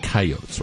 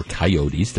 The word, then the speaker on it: coyotes: One of the things, and we have talked about this before, is over the last few years, there's been a larger presence of coyotes, or coyotes, depending on where you grew up and how you want to pronounce it, in the urban areas.